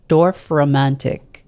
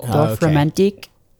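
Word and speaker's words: Dorfromantik: How do you say it? Because I can't read it. – Dorf Romantik. – Ooh. – Dorf Romantik. It's German.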